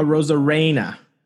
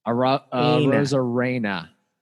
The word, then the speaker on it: Arozarena: I think I just got Arozarena. – Arozarena. – Arozarena.